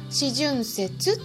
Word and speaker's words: 四旬節: 四旬節って読むんですけどこれあのイースターの前の時期なんですね。